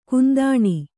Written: ಕುಂದಣಿ

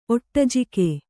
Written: ಒಟ್ಟಜಿಕೆ

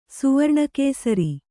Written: ಸುವರ್ಣಕೇಸರಿ

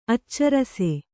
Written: ಆಚರಿಸು

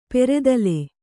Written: ಪೆರೆದಲೆ

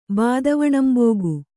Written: ಬಾದವಣಂಬೋಗು

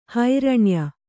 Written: ಹಿರಣ್ಯ

